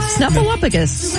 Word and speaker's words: Snuffleupagus: Snuffleupagus? – I do. – Am I even saying that right? – Snuffleupagus.